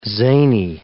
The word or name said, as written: zany